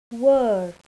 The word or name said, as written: were